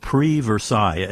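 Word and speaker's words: Prix Versailles: Well, there's a group called Pre-Versailles. And as my French-speaking daughter said, it's spelled P-R-I-X, Pre-Versailles.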